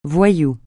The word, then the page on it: voyou: francais